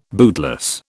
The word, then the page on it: bootless: British English